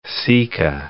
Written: sika

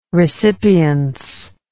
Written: recipience

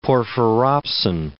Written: porphyropsin

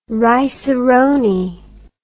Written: rice a roni

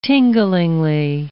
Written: tinglingly

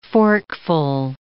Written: forkful

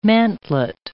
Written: mantlet

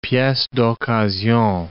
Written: piece d'occasion

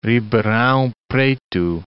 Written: Ribeirao Preto

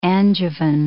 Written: Angevin

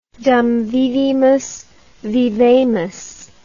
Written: dum vivimus vivamus

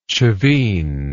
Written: chavin

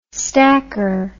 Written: stacker

